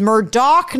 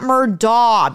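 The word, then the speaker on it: Murdaugh: A lot of people mispronounce their name. It is Murdoch, not Murdaw, because people in the South don't know how to pronounce their own names.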